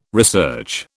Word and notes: research: British English

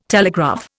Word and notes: telegraph: Indian

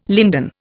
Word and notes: linden: Indian

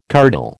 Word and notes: curdle: American English

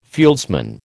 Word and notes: fieldsman: American English

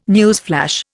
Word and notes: newsflash: Indian

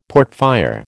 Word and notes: portfire: American English

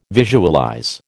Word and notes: visualize: American English

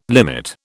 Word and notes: limit: British English

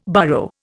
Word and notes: burrow: Indian

